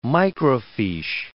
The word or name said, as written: microfiche